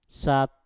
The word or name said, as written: sat